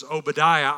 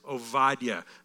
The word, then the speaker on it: Obadiah: Okay, you might see it, or we've always pronounced it as Obadiah. I have. It's kind of the American way to say it. The Hebrew pronunciation is Obadiah.